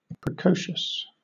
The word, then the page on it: precocious: British English